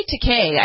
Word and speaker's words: Takei: George Takei is the latest, or maybe Takei. I think that's how he says it, but William Shatner ruined his life by calling him George Takei for a long time. So we'll go with his pronunciation.